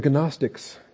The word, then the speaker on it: Gnostics: The Gnostics, if you want to pronounce the G. It's a silent G. But the Gnostics and Gnosticism.